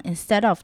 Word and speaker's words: instead: 再来看到 cleaner， EA 的长音要注意 E 这个字呢，我们可以跟下面的 instead of 的 instead 做比较， instead 也是 EA，但是呢它的 EA 是短音的 A，所以呢，我们可以看到 EA 呢，它大致上会有两种发音，一种就是长的 E 的音，而一种就是像 instead of 的短的 A 的音。